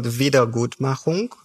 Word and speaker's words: Wiedergutmachung: So the word is called Wiedergutmachung, Wiedergutmachen, and it literally means what you said.